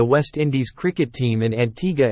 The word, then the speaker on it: Antigua: Antigua, pronounced Antigua, and Barbuda, Barbuda, is located in the heart of the Caribbean Sea.